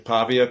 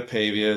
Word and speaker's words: Pavia: Pavia, Pavia. It depends on what part of the country or world you're in.